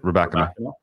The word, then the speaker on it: Rybakina: And I'm all in Elena Rybakina. Have we ever figured out the pronunciation? I feel like we've just gone back. – Yeah, no, that's it. That's it, Rybakina.